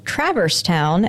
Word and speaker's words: Traverse Town: In Kingdom Hearts, there's a place called Traverse Town, and it is pronounced like you'd put Travis and Converse together.